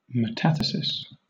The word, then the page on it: metathesis: British English